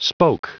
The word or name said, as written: spoke